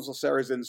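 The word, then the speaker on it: Sarrazin: He's a con man in this, and he shows up, the Michael, I can't pronounce his name, is it Sarazin, plays Curly. – Sarazin sounds correct.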